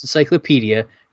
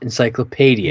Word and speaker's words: encyclopedia: It's pronounced encyclopedia, not encyclopedia.